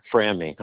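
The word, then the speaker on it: Franmi: It's spelled F as in Frank, R-A-N-M-I. It's like Frank without a K. – Okay, Frangmi.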